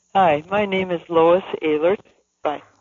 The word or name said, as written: Lois Ehlert